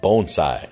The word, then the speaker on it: bonsai: And according to Mr. Miyagi in Karate Kid, it's pronounced bonsai. So bonsai tree.